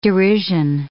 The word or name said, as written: derision